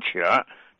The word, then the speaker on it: 客: 我举一个例子啊，来钱儿了，来客人了，客人的客读成钱儿。